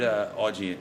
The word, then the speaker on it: Augean: Because I'm curious, where did Augean, am I saying it right? – Yeah. – Where did you get the name for Augean? – So, there's the Augean stables.